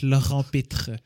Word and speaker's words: Laurent Pitre: That's why I made sure to pronounce it extra French, Laurent Pitre.